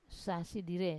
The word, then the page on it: sasi dire: wamesa